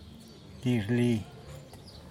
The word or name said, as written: dizhli